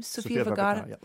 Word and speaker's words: Sofia Vergara: Fans of Sofia Vergara tried, is that how you say her name? – Sofia Vergara, yep.